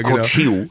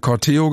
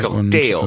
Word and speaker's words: Corteo: Ja, es ist italienisch. Es heißt so viel wie Umzug und es wird zumindest laut einiger Ausspracheseiten inklusive Hörbeispiel Corteo gesprochen, also Betonung auf dem E. – Corteo.